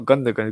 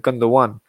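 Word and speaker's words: Gündoğan: I'm like, oh, shoot, it's not Gundogan, it's Gundogan. So apologies.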